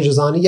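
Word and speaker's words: Androzani: So is he in the case of, uh, how do you say, Adrazani? – Adrazani. Yeah, he's, a, he's in the regeneration scene, but that's it.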